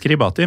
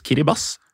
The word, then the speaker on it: Kiribati: Ja, og det skrives Kiribati, men jeg lærte på den harde måten. Uttales Kiribas.